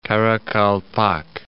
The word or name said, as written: Karakalpak